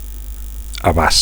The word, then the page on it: abast: Catalan